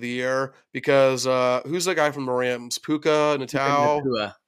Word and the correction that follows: Puka Nacua: How do you say it? – Puka Nacua.